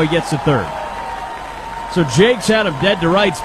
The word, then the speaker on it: Jacques: Joe Jakes, spelled J-A-C-Q-U-E-S. Looks like Jacques, but it's pronounced Jakes.